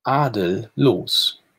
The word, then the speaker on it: Adellos: wie spricht sich's aus? Adellos. Adellos. – Also ohne, ah. ohne Adel.